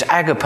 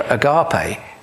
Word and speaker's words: agape: Now, it says have fervent charity among yourselves, but the word here is agape or agape, whichever way you want to pronounce it.